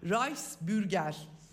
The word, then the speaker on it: Reichsbürger: Reichsbürger Evet.